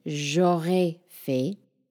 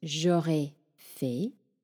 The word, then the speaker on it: j'aurais fait: So these sound exactly the same, but they're spelled differently. The one that means I would have done or I would have made, j'aurais fait, is spelled J apostrophe A U R A I S F A I T. And the one that means I will have made or I will have done and is pronounced j'aurais fait also, is spelled exactly the same way, but without the S. So it's J apostrophe A-U-R-A-I and then F-A-I-T.